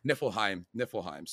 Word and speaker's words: Niflheim: but of Asgard and Niflheim. Did I say that right? – Niflheim.